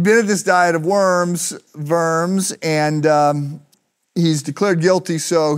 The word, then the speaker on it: Worms: The Diet of, it's spelled Worms. You're supposed to say it with the V, the Diet of Worms.